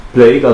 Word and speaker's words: Plague: Nee, ich finde es gerade nicht, aber Play E-I-N-S, oder? Play-ins. – Plague, also wie Pest. – Ach so. Wie schreibt man das? P-L-A-G-U-E.